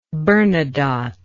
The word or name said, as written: Bernadotte